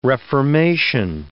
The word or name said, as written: reformation